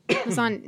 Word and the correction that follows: Missandei: No, there's no R. – Yeah, or an A.